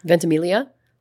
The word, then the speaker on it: Ventimiglia: Ventimiglia.